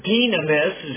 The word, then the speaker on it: dynamis: It's the Greek word dynamis, is actually the way they pronounce it, I think. But they translate the U, or transliterate the U with a Y.